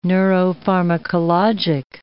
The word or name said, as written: neuropharmacologic